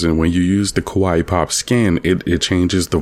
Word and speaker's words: Kawaii: Now with Bastet, her skin that I love to play with her is it's called Kawaii Pop. It's like the word Hawaii, but spelled with a K, because it's like K-pop Hawaii.